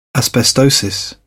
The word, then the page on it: asbestosis: British English